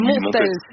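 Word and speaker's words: Montels: Montel, on prononce le S à la fin? – Alors Montel, oui, Montel. C'est très important de prononcer toutes les lettres.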